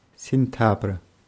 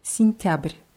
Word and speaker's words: сентябрь: Sintabr. – Ja, wird mit je vorne geschrieben. Dieses je wird aber als i ausgesprochen, weil es unbetont ist. Sintabr. – Sintabr.